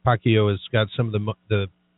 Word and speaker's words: Pacquiao: I've got Steve Wilmoth with me, but I've got a little bit on Floyd Mayweather and Manny Pacquiao, or Pacquiao as Rob told me to pronounce it.